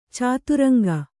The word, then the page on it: ಚದುರಂಗ: caduraŋga